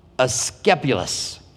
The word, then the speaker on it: Asclepius: It's kind of like what they used it for, to the god, and I want to make sure that I pronounce his name correctly, to the god Asclepius. I've been saying his name wrong for many,